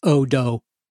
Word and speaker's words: hot dog: Like but that word in particular shouldn't be used in French because in French it's odo. Oh no, because there's no H and there's no, yeah. It's so silent. All you're left with is the O and the D and the O.